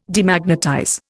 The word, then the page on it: Demagnetize: Indian